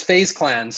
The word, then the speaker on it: FaZe Clan: What is that, the Fozzy Clan? Am I even saying it correctly? – It's FaZe Clan.